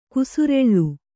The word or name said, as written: ಕೇಸರಲ್